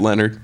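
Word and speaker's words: Lynyrd: It's pronounced Leonard.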